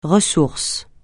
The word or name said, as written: ressource